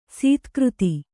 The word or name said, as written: ಸದಾಗತಿ